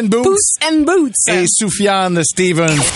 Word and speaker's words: Sufjan: Soufiane Stevens, S-U-F-J-A-N Stevens, comme euh, Stevens, comme vous le prononcez.